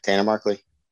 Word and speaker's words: Tanner Markley: Yeah, Tanner Markley.